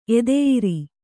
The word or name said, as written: ಎದೆಯುರಿ